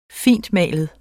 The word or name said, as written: fintmalet